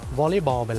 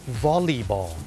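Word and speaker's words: volleyball: วอลเลย์บอลไปแล้วเนี่ยคนไทยเนี่ยเมื่อพูดถึงกีฬาวอลเลย์บอลเนี่ยออกเสียงว่าวอลเลย์ถูกไหมครับวอลเลย์บอลแต่จริงๆแล้วนะครับเจ้าของภาษาเนี่ยไม่ได้ออกเสียงว่าวอลเลย์บอลนะครับเขาออกเสียงว่าวอลลีบอลวอลลีบอลตัว LEY เป็นออกเสียงลี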